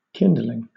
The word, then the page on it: kindling: British English